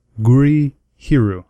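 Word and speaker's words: Gurihiru: This week we read The Search by Jean Luen Yang and Guri Hiru. Guri Hiru. I said it right that time, for sure. – That's correct.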